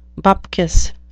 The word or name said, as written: bupkis